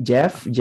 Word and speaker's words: Geoff: But yeah, so G, no, no, no, it's G, Geoff. Sorry. Oh, Geoff, thank you for taking the time. Sorry, I'm like, it's just like the Spanish maybe is just forcing me to try to pronounce letters that maybe that, you know, I don't have to pronounce.